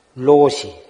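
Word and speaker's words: 로시 (老師): 통과해야 로시, 로시는 노사, 늙을 노자, 스승사, 한국에서는 조실스님이라 그러는데 일본에서는 노사라 그래. 일본 발음으로 로시라 그러는데 천 개를 맞추면 로시가 될 텐데 자기는 아직 500개 뱉기는 못해서 로시 자격은 못 따고 그냥 참선을 지도할 수 있는 자격만 받았다고.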